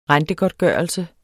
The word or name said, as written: rentegodtgørelse